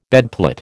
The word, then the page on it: bedplate: American English